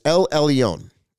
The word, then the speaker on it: El Elyon: You pronounced that wrong. It's El Elyon. El Elyon. Okay. Elroy. Elroy. Who's that? Elroy. E-L and then R-O-I.